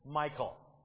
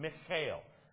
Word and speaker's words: Michal: Now, I told you this morning as an introduction to the message, I'm going to pronounce it the way we as Americans pronounce it, Michal. That is not the Hebrew pronunciation. I'm not a Hebrew, so I'm not going to worry about the Hebrew pronunciation. But it's something along the area of Michal. If you talk to a Jew or a Hebrew, that's how they would pronounce her name, Michal.